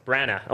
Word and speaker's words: Branagh: Branagh. Okay, I know I was pronouncing it wrong, but this is the third movie in the series.